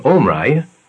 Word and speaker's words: Omri: The Hebrew name Omri begins with the consonant Y called Ayin, which is pronounced with a guttural H, and is represented in Assyrian transliteration as G or K.